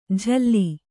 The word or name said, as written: ಜಲ್ಲಿ